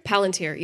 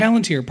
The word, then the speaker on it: Palantir: And in the book two, he ends up at the Two Towers grabbing the Palantir. Did I say that right? – Palantir, yeah. – Palantir, pardon me.